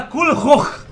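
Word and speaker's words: Culhwch: Se fala Kulur, escarrando, assim, coisa de escocês, sabe? – E o Mordred, como se fala?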